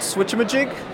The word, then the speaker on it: Switchamajig: The app is called Switchamajig. – Okay. – That's like spelled like thingamajig, but with a switch.